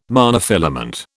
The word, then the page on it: monofilament: British English